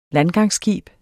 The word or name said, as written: landgangsskib